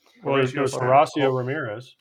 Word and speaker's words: Horacio Ramirez: How do you say that name? Hmm? – H O R A C I O. – Horatio. – Horatio. I, there you go. – Horacio Ramirez. Horatio.